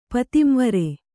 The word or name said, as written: ಪತಿಂವರೆ